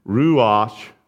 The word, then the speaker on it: ruach: Now, in a lot of the English translations, it says wind there, but that's the Hebrew word, and I'm probably not pronouncing it right, but ruach, R U A C H, and I didn't use all the guttural stuff.